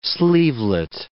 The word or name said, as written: sleevelet